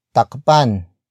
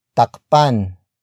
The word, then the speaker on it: takpan: Word for word, it's takpan mo ang ulam. Takpan mo ang ulam. Takpan mo ang ulam. The first word is takpan. Takpan. Takpan. Takpan is spelled as T-A-K-P-A-N.